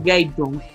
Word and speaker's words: Gabetunez: Gaytun. ¿Qué más, Gabriel? Cuéntanos. – Aquí mismo, todo bien, bro. Bien, bien. Lo pronuncié bien, ¿no? Gaytun. – Sí, sí, está bien, bro.